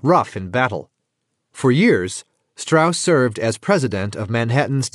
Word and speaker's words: Strauss: Proud of both his Jewish and his Southern heritage, Strauss, spelled Strauss, pointedly insisted on pronouncing his last name as Strauss.